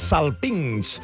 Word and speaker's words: salpinx: Això és el salpinx. No sé ni com pronunciar-ho. – Salpinx. – Amb X final.